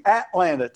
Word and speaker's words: Atlanta: But if you go to Atlanta, they'll think you're a native if you say, I'm visiting Atlanta, Texas. You have to put the emphasis on the at, Atlanta, Texas.